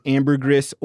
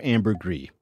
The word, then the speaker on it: ambergris: Now here, this says, Webster says you can pronounce it ambergris or ambergris. So there we go. Either way will work.